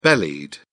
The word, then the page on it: bellied: British English